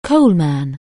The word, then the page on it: coalman: anglais